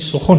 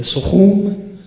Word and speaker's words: سخن: البته میدانید تلفظ صحیح اون در پارسی سخن است دیگه، سخون سخن بعد تبدیل به سخن ممکنه نه.